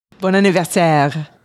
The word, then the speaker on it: bon anniversaire: Did I say that right? Was that Canadian? You're close. Okay. – Well, how do you say it? I don't like being close. I like being right. – Bon Anniversaire. – Oh, Bon Anniversaire. You got to say it with that proper Quebecian, French, Canadian.